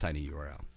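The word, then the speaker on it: TinyURL: Go to Tiny Earl fucking. – Nice. – I think that's supposed to be pronounced Tiny URL.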